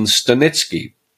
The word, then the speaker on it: Stanecki: I interviewed Adam Stanecki, or the proper Polish pronunciation, Stanecki, and he calls himself the breath geek.